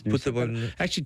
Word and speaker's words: Nusa: I'm flying to Bali in a couple of weeks and travelling with Noosa, N-U-S-A. Is that how you pronounce it? Noosa? – Noosa, yeah, Noosa.